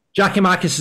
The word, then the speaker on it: Giakoumakis: Giakoumakis is a very good pronunciation.